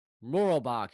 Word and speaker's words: Moorabbin: Moorabbin. You gotta say it like Moorabbin. – Moorabbin. – You gotta enunciate the three syllables.